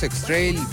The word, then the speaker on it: X-Trail: ¿Así se pronuncia? ¿X-Trail? – Así es. Tenemos X-Trail, Versa, March.